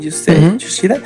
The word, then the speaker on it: Yoshida: Pero ya dijo este, no sé si lo estoy pronunciando bien, este Yoshida, eh, Yoshida.